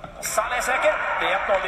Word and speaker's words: Salah: Ska det sägas Bruno Fernandes och Timo Werner, då ska det ju också på bästa, alltså Sveriges radiokorrespondent i Mellanöstern, Salah självklart uttalas med de arabiska A-na. Alltså, du uttalar Salah som Hezbollah, Hamas, Abu Bakr al-Baghdadi.